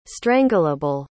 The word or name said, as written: strangleable